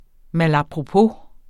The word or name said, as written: malapropos